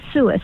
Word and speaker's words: Suis: My last name is Suis. It's like Lewis with an S.